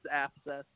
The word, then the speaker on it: Afseth: Yeah, it's Afseth, but yeah, that's close.